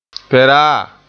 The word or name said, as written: peraa